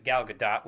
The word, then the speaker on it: Gal Gadot: Wonder Woman, Gal Gadot, or Gal Gadot. I think I've heard her pronounce it Gal Gadot, which makes me want to scream.